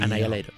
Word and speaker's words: Annihilator: deberíamos decirle Annihilator. – Bueno, pronúncielo usted, a mí no me sale así. – Sí, Annihilator.